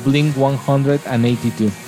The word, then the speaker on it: Blink 182: ya que el nombre de Blink 182 se pronuncia como Blink 182, ¿no?